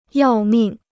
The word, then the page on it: 要命: yào mìng